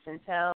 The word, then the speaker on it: Cientell: My name is spelled CIENTELL. That's pronounced Centel.